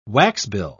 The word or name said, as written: waxbill